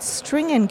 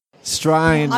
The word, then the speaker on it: Strine: I've been pronouncing it String & Co. – String? Strine? – String? String? Josie, help us out. – Strine. – I was right. – Strine & Co. There we go.